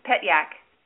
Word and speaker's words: Petyak: It is pronounced just like it reads, Petyak.